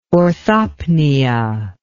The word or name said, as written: orthopnea